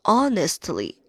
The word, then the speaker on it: honestly: 注意这个 honestly h 不发音，不发音啊，相当于 our。